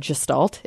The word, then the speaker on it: Gestalt: Gestalt. – Yes, it's Gestalt. It's Gestalt. German. It's Gestalt. I don't know. Literally every psych professor I've ever had living in the South said Gestalt.